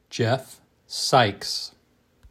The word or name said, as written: Jeff Sikes